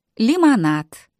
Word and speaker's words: лимонад: In English we say lemonade, and in Russian they pronounce it лимонад. – That last letter is actually a D, but the way Russian pronunciation works, it gets what's called D-voiced and sounds almost like a T.